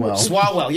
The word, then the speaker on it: Swalwell: Do you think it's a great night for somebody like Eric Swalwell, who I think I don't even say his name right? – We know his name. – Swalwell, yeah.